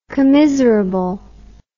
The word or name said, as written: commiserable